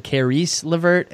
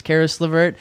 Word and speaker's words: LeVert: I think you said Caris LeVert. It's Caris LeVert.